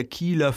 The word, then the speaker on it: Kyle: Und es das heißt die Holstenstadt an der Kieler Förde. Das heißt das übersetzt, ja. Und damals hat man das noch mit so langem I ausgesprochen. Also K-Y-L-E wird es geschrieben, dann Kiele, Ne?